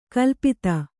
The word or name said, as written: ಕೊಲೆಪಾತ